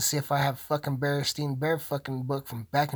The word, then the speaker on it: Berenstain: That's right, Berenstain Bears. But why is it called the Berenstain Bears if it's spelled Berenstain? B-E-R-E-N-S-T-A-I-N. Berenstain Bears is what it's called now.